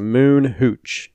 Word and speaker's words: Moon Hooch: That's a Moon Hooch. Exactly how it sounds is how it's spelled.